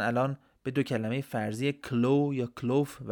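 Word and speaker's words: Klow: داخل داستان میبینیم هرژه اسم پایتخت سیلداویا رو گذاشته کلو شاید هم با تلفظ لهستانی باید بگیم کلوف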